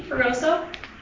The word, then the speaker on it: Fragoso: I'm Lindsay Caruso, general counsel for Haven Health. – I'm sorry, what is your name again? – Lindsay Caruso. – Could you spell it, please? – Sure. L-I-N-D-S-A-Y. Last name F-R-A-G-O-S-O.